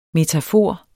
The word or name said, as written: METAFORERS